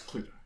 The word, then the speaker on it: Cluedo: I think it should be pronounced Cluedo. Anyway, it's Cluedo. – What? – In America, it's just Clue. And I'm like, why do we say do if it's D-O? Usually that's do. And then it would rhyme.